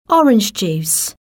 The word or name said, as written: orange juice